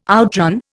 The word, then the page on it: outran: Indian